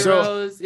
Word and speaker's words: gyro: It's heroes if it's food. It's a gyro if it's in science. – So my understanding is that with the Greek G, that's kind of pronounced more like an H, right?